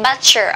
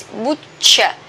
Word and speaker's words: butcher: Don't say butcher, okay? This is butcher, butcher. Short u, butcher.